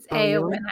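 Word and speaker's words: Éowyn: It doesn't sound like it would be pronounced like that, but it is actually pronounced Eowyn.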